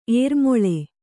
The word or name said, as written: ಏರುಮೊಳೆ